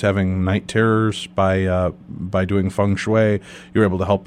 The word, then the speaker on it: feng shui: This evening, we're talking to our guest, David Weiss, and we're talking to him about the ancient art of feng shui. Am I pronouncing that correctly, David? – Yes, you are.